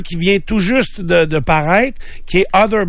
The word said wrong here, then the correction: Outer: Banks. Ça s'écrit O-U-T-H-E-R, puis Banks. Ça se passe en... Hein? Other? – T-H-E-R? Ou T-E-R? – T-A-R, peut-être. – Ah, c'est outer.